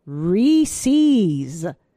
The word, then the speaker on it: Reese's: You 100% pronounce it as Reese's.